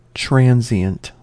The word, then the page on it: transient: American English